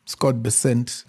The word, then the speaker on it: Scott Bessent: Scott Bessent, I think that's the correct pronunciation.